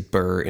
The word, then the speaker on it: BRRRR: And you're like, what in the world is BRRRR investing? Well, BRRRR investing is B-R-R-R. That's how it's spelled, like BRRRR, like you're cold.